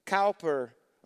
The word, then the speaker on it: Cowper: William Cowper, or Cooper, depending on how you say it, who wrote so many God Moves in a Mysterious Way, all the awesome songs, tried to take his life three different times.